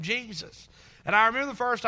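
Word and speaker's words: Jesús: And someone informed me that it's pronounced Jesus, and I get it.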